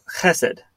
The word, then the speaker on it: chesed: and it's chesed. And I think I pronounced that right with the good phlegm at the bottom there. Mm-hmm.